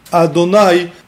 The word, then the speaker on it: Adonai: And that's why we pronounce it Adonai, which signifies that he's the master of everything.